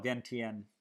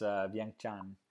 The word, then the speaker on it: Vientiane: Some people call it Vientiane. That's how it's spelled in English, but it's Vientiane.